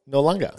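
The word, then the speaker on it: Noarlunga: Port Nullunga. – That's a very good pronunciation.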